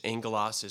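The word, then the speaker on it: Angelos (ἄγγελος): Angelos is how you say it in Greek, and angel is how you say it in English.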